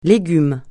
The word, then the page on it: légume: francais